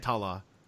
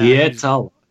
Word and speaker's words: Hietala: Let me do the pronunciation here. – Hietala. – What do we got? Hietala. So I have. – Hietala.